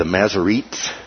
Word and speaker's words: Masoretes: The entire Hebrew Old Testament copied by the Masoretes is the way it's pronounced.